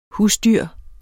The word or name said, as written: husdyr